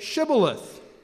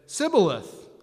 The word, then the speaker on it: Shibboleth: And when he said no, they said to him, then say Shibboleth. And he said, Sibboleth, for he couldn't pronounce it right.